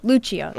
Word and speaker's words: Lucio: Lucio.